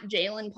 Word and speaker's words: Jaelin: Yeah, so my Instagram is at Jaelin Palmer, and my it's just my name, but my name is spelled J A E L I N, and then Palmer.